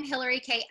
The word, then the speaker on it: Hillarie Kay: So I am Hillarie Kay everywhere.